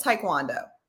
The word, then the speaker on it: Taekwondo: World Championship Taekwondo.